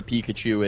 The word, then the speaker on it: Pikachu: Yes, I don't know what a Pikachu is. I know it rhymes with Waterloo, which...